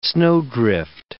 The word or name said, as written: snowdrift